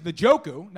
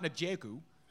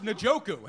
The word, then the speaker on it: Njoku: Okay, so his name is Njoku, not Njoku. So the top 5 tight end next year that was just selected couldn't be pronounced properly by the guy who said it. – He doesn't want that pick now. – Right. You ready for this? It's pronounced Njoku. How about that? – That is a good pick.